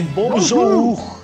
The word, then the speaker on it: bonjour: É, bonjour! – É assim que pronuncia.